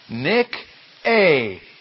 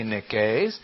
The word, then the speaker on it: Nike: And so, you know, that is pronounced Nick-A, Nick-A. And, of course, in English, we don't say Nikes. We call them Nikes.